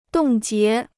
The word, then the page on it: 冻结: dòng jié